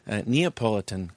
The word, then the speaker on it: Neapolitan: You know, and when I gain weight, they even pull the Neapolitan card. Is that what it's pronounced? – That's exactly right.